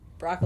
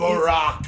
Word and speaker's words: Brock: So I hate the way that he says Brock Lesnar. Like Barack. – Because he says Barack. There's an extra syllable.